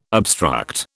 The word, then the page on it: obstruct: British English